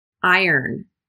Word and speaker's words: iron: Sounds as if it's spelled I-E-R-N, not I-R-O-N.